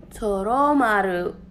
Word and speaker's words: Toromaru: Ew, I said the name wrong. Pause, rewind. I will not disgrace his name like that. The good old doggo, Toramaru. I said the name wrong. I'm upsetting myself, guys. Pause, rewind, redo. Toromaru, there you go.